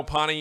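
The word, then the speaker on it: Pahhni: We have Pawnee Water.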